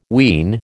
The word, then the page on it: ween: American English